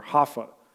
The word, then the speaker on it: Jaffa: we would say Joppa, they would call it Jaffa, or Haffa would be how they would pronounce it.